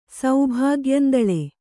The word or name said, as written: ಸೌಭಾಗ್ಯಂದಳೆ